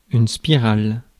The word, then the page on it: spirale: [spi.ʁal]; France